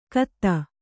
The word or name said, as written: ಖತ